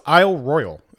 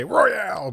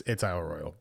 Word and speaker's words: Isle Royale: But so no, it's Isle Royale, and you know the way it's spelled, you're gonna say Royale, but it's Isle Royale.